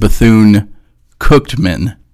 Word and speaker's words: Bethune-Cookman: By the way, I pronounce it Bethune-Cookman. – Cooked. – Cookman, yeah, is the way I do it. It's Cookman, but yeah.